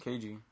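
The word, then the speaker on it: Keiji: 9 made all their crazy announcements because, how do you say his first name? Kiji? KG? – KG. – KG and a...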